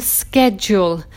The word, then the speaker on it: schedule: Schedule spelling is S-C-H, the same as school. And on day three, I focused on this K sound, like chemist, architect, psychologist, mechanic. All of them have the K sound, but the CH spelling. Now, one word I didn't include was schedule, because some people, or Americans, I think, pronounce it schedule.